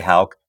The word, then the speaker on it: Hauck: They say Hawk, but of course you know who. We've known each other for so long, and you say Hauck beautifully.